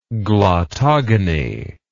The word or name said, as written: glottogony